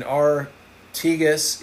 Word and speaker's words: Artigas: And the Erfoud is spelled E-R-F-O-U-D. Uruguay in the Artigas and Pasadu in Uruguay. And Artigas is spelled A-R-T-I-G-A-S. And maybe it's Paysadu. It's P-A-Y-S-A-D-U in Uruguay are known and famous for their amethyst crystals.